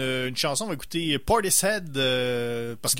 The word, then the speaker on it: Portishead: c'est Portis Ed, Parce que le mot head en anglais, c'est quelque chose.